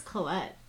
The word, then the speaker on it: Collette: Is it Collette or Colletti? That was my first question for you guys. – It is Collette. – I've been saying Colletti for a long time. – Oh, it's okay.